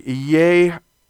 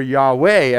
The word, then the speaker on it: Yahweh: So if it's a W, an O, or a U, you would have something like Yahweh, or Yahweh, as they pronounce it today. You hear a lot of theologians say Yahweh, right?